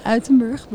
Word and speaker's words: Uitenbroek: by Eli Uitenbroek and Ari Versluis. Please correct me. – You're very close. – All right. – It's the Versluis and the Uitenbroek, but it's very close.